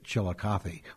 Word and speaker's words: Chillicothe: But that's where he was born, and that was within two arrow flights of where the big village of Chilagatha, which we always pronounced Chillicothe, was located.